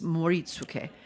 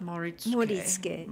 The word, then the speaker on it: Moritsuké: They're pretty flat. Yeah. And some people try to find where the accent goes, and the answer is nowhere. It's flat. – Moritsuké.